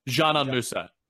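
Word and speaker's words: Dzanan Musa: And there's been this guy, it's spelled D-Z-A-N-A-N. Is it Zanan? Zanan Musa? Is that how I say it? Zanan Musa. – Yes.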